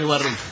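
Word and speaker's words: Güerrín: Aquí alguien pregunta, ¿cómo se pronuncia, Guerrín o Guerrín? – Se pronuncia Guerrín, pero nadie lo sabe. – Sí, es Guerrín. Además tiene la diéresis.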